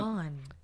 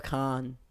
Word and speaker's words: Kahan: Noah Kahan. – Khan. – Kahan. Then why is the Khan. H there? – That's what I'm saying. – Because it's a silent H.